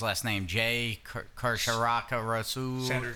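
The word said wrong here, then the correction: Chandrasekhar: Chandra Sekar. – Chandra Sekar.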